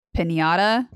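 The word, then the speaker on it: piñata: She's like a piana. did I say that right? Pinata. Pinata. Did you say pinata? – Maybe.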